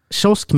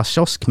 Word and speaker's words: kiosk: Men då är det, den som uttalar kiosk med ett K stör sig på den som uttalar kiosk med ett sh och vi säger ju sh, mm.